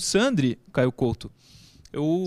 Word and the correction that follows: Sandro: Sandro. confio muito. – Hã? Não, você falou Sandri, é o Sandro.